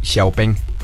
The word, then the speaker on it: Xiaopeng: De hecho, en China lo pronuncian así, Xiaopeng.